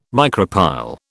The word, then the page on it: micropyle: British English